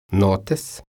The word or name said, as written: notes